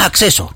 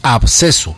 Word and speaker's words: absceso: Vea, entonces, no es acceso. – Es absceso. – Absceso, se escribe A, B alta, S-C-E-S-O, absceso, absceso.